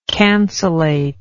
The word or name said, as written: cancellate